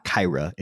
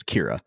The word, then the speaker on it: Kyra: I think it's hilarious that Tyra Banks plays a character whose name is spelled K Y R A and it's not Kyra, it's Kira. Just do Kyra.